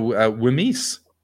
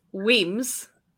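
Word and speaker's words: Wemyss: I'm going to go Wimis. – So the first one is pronounced... – John. – The first one is pronounced Weems.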